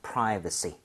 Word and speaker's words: privacy: 그래서 privacy. 대신에 발음할 때 V가 나왔습니다. – 네. – 프라이버가 아니라 입술이 붙으면 안 되죠. 자, V는 F와 비슷하게 입술이 붙으면 안 돼요.